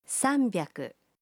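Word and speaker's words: sanbyaku (三百): Mais la prononciation peut être trompeuse. Pour 300, on dit « sanbyaku ».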